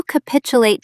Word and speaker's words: capitulate: When you capitulate to demands, you yield the field. Stress the second syllable. C-pitch-a-late. Try saying it.